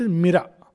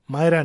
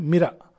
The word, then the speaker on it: मीरा: मीरा that's how it is pronounced मीरा मायरा नहीं मीरा M I double R A